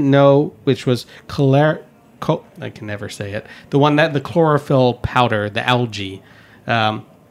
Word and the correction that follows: Chlorella: Chlorella? – Yes, that's the one. Butchered that well.